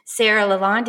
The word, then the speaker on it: Sarah Lalonde: Today I have a new STEAM friend with me, Sarah Lalonde.